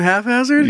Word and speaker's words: haphazard: Half-hazard. – Saying haphazard? – Yeah.